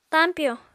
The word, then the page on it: tañpyo: apatani